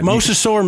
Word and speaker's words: mosasaur: Mosasaur. – That. – You said it right. – Mosasaur, mesosaur. – You said it right the first time.